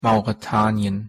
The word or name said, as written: mauretanien